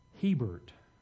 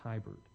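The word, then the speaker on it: Hiebert: I've been told that you pronounce the last name Hebert. I've always pronounced it Hibert.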